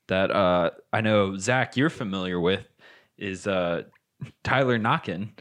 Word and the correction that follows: Naquin: Naquin. – You know how to pronounce words, unlike Joey Votto.